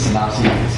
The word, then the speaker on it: synopses: I'm not sure if it's pronounced synopses, but that is the plural. I'll look it up. It's not synopti. It's synopses.